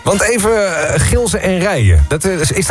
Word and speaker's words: Gilze en Rijen: En het is echt officieel Gilze en Rijen. Zo moeten we het uitspreken. – Ja, Gilze en Rijen.